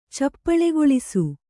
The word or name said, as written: ಚಪ್ಪಳೆಗೊಳಿಸು